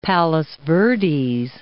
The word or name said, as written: Rancho Palos Verdes